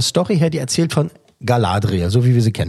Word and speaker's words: Galadriel: Galadriel, hier wird so ausgesprochen, Galadriel.